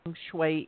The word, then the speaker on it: feng shui: feng shui is and what some of the principles are? – Sure. Feng shui is spelled F-E-N-G-S-H-U-I. Feng shui. And the original spelling was the way it sounds, but I started using that with my company at first, and it was just too confusing with everyone.